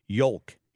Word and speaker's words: yolk: Somebody also pointed out that yolk is the correct pronunciation of that word, egg yolk.